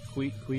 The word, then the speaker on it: Kwadwo: Quijo. But it's spelled K-W-A-D-W-O, and I said Quadro. But it's Quijo.